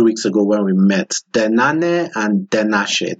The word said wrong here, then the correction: denanesh: Did I pronounce denane? Denanesh. – Denane, denanesh. Okay, yes. not denache. Denanesh.